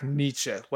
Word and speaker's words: Nitzsche: I'm going with Jack Nitzsche. Like the philosopher.